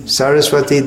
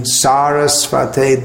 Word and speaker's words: Sarasvate: Sarasvati Devi is a goddess who we worship, but in this context, in this pranam mantra, we should be chanting Sarasvate Devi.